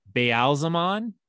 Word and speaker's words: Ba'alzamon: So then we get the appearance of the Dark One, and the Dark One identifies himself as Baalzamon.